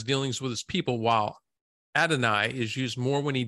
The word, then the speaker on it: Adonai: Did I say that right? Adonai? – Yep.